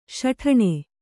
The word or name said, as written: ಸಟ್ಟನೆ